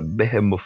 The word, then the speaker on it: Behemoth: Ela tem um nome, uma escrita diferente, mas a pronúncia é essa, Behemoth.